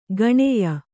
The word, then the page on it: ಗಣೆಯ: gaṇeya